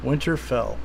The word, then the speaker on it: Winterfell: Winterfell, right? Am I saying it right this time? Okay. – Winterfell.